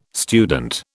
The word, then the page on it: student: British English